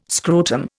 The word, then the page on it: scrotum: Indian